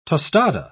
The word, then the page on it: tostada: British English